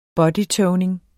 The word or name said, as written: bodytoning